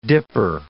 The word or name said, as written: dipper